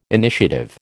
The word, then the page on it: initiative: American English